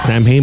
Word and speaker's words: Samhain: It's Samhain, but it's pronounced Samhain.